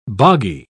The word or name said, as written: boggy